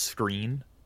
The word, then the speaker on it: Skrine: Bryce Callahan going to Denver, who was their good player there, and now they're replacing him with Buster Skrine. – Mm-hmm. – By the way, $21 million contract. – technically pronounced screen.